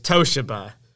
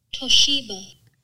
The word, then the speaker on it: Toshiba: I claimed that the way to pronounce Toshiba was Toshiba,